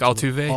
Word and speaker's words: Altuve: I think Altuve.